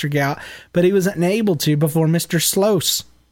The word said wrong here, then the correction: Sluice: did I say it right? Sluice.